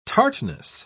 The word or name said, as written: tartness